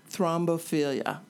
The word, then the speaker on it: thrombophilia: Thrombophilia. – Thank you. I will not say that again. What is it, and what do expecting parents need to know about it? – Well, thrombophilia is a tendency toward having blood clots.